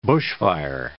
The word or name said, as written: bushfire